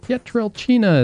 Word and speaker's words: Pietrelcina: He said, "Pietrelcina is the right way to say it."